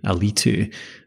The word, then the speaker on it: Alitu: I don't know, it's something about the accent and the emphasis that they emphasize the I, Alitu.